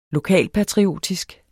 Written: lokalpatriotisk

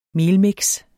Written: melmix

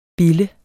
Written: bille

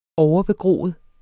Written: overbegroet

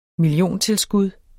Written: milliontilskud

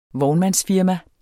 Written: vognmandsfirma

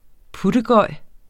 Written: puttegøj

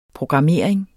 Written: programmering

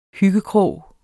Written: hyggekrog

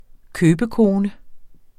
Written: købekone

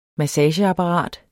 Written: MASSAGEAPPARAT

